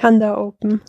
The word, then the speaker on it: Handa Open: Ich meine, du bist ja ein blau-weiß Hand und das Ding heißt dann Handa Open oder Handa Open? Wie spricht man das aus oder wie spricht ihr das aus? – Die Handa Open.